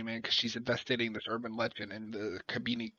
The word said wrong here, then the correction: Cabrini: I'm gonna say this wrong. Cabini Green, Cabrini Green.